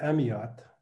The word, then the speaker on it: Amyot: Did I pronounce it right? – Well, I would say Amyot.